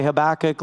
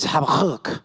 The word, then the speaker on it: Habakkuk: Some say Habakkuk, some say Habakkuk. Let me just tell you, we're all saying it wrong. I studied the Hebrew, and the accurate pronunciation is Habakkuk. Habakkuk. I'd have you try that, but you get something on the person in front of you. So to say it right, you got to get a little something right in your throat right here, a little phlegm going.